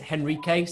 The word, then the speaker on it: Henrique: It's Henrique. So it's like, think of Henry with a K at the end.